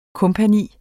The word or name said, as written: kompagni